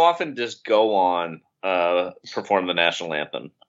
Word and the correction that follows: Gowan: Am I pronouncing his name right? – No, you're not. Gowan, I think, is who you mean to say. – Oh, Gowan, yes.